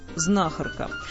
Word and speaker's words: знахарка: Ударение там же, где и в мужском аналоге слова, на первом слоге. Знахарь, знахарка. Правда, по секрету, для самых любопытных могу сказать, что последний словарь грамматических трудностей русского языка допускает ударение знахарка. Это значит, что ошибка, собственно, не будет грубой, хотя знахарка все равно лучше.